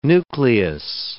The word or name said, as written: nucleus